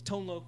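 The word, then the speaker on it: Tone Lōc: Tone Loke was one of them. – Yeah. – And the Loke has a line over the O to make sure you know it's a long O.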